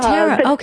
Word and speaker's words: Tara: Well, it's Tara with a T, like Tuesday, but that's okay. – Oh, Tara.